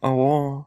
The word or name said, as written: awao